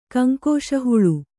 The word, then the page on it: ಕಂಕೋಶಹುಳು: kaŋkōśa huḷu